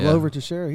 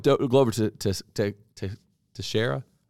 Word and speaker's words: Teixeira: You know, I don't believe Glover Teixeira. Was saying that? – I think that's how you say his name, yeah. – Saying that right. I don't believe Glover Teixeira ever made an amount of money where he could quit.